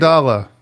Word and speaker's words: amygdala: Amygdala. Amygdala.